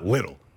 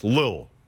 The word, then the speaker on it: Lil: It's not Little. It's Lil, all right? I go Lil Wayne. – Okay. – And a little baby's coming up real fast. – Apologies for enunciating. My bad. – it's spelled Lil. L-I-L.